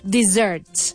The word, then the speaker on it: dessert: The remake or this dialogue is dessert. Dessert. Dessert means postre. Okay? Don't confuse it with desierto. How do you say desierto? – Desert. – Desert. So dessert, desert. – That's right. Don't eat your dessert in the desert.